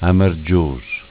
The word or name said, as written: Amargios